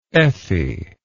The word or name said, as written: effie